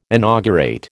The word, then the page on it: inaugurate: American English